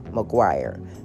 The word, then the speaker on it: Maguire: And just for reference, since you guys can't see it, one's last name is spelled McGuire, and the other one's name is spelled Maguire. But they're both pronounced McGuire. McGuire and Maguire.